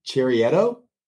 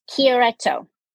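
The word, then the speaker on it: Chiaretto: We're going to get that kind of explanation as we pour this 2022 Valtanesi del Garda Cherietto. – Okay. Oh, this is going to be fun. – Did I say it right? – It's Valtanesi and it's Chieretto.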